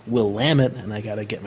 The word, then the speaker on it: Willamette: And then it's Willamette. – Willamette. – Willamette. Well, yes, because it rhymes with damn it. – That's pretty close Willamette.